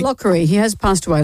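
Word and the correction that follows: Locorriere: Let's say his name correctly, she said, looking for the piece of paper. Lockerrière.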